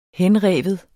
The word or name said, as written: henrive